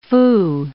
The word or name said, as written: fou